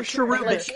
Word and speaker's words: cherubic: Cherubi? – Cherubi? Like, oh, yeah. – Oh, cherubic.